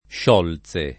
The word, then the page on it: Sciolze: lingua italiana